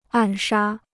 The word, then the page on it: 暗杀: àn shā